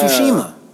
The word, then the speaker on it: Tsushima: It's Tsushima. – Oh, Tsushima? – Yeah. The T is silent.